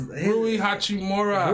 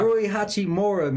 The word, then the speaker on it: Rui Hachimura: I don't know how to say his Rui Hachimura. – Rui Hachimura, man.